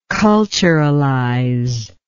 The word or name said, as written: culturalize